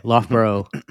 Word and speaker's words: Loughborough: Loughborough? – I would say Loughborough, yeah. – Okay, Loughborough I'm not familiar with it, I can't say.